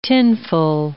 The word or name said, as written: tinful